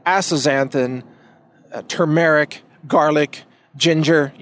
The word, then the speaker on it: turmeric: And by the way, it is pronounced turmeric. Please don't pronounce it turmeric. People like to say it's a tumeric, which isn't really how it's spelled. It's spelled T-U-R-M-E-R-I-C, turmeric or turmeric, but not turmeric. So just that clarification. I hear a lot of people mispronounce this word. I know it's kind of a crazy word, so just try turmeric, with the emphasis on the second syllable, turmeric.